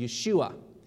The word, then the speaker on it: Yeshua: And Jesus is a shortened form of that, and it's called Yeshua.